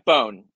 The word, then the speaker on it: Bohn: Mike Bone is what we've been going with.